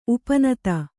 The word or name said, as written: ಉಪನೀತ